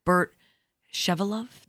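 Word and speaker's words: Burt Shevelove: This is a Sondheim a play, and the book is by Bert Shevelov. Did I say that right? – Yes.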